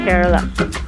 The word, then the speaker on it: Kerala: I'm from Kerala.